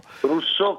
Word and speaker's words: Rousseau: Russo con l'accento sulla O, vero? – Russo, che era facile quando al liceo ti dicevano Rousseau, no? Che tu non ricordi così, si scrive Rousseau, russo.